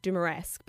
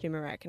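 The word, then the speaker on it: Dumaresq: It sounds like Dumeresque, but it's actually pronounced Dumerec.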